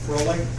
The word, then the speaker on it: Froelich: My name is Carter Froelich. The last name is spelled F-R-O-E-L-I-C-H.